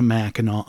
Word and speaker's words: Mackinac: Anyone who grew up in Michigan and went on family vacations to Mackinac Island knows that Mackinac ends with a silent C, not a W.